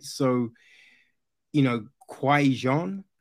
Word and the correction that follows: Qigong: is that how you say it? Kwai Jean? – Qigong.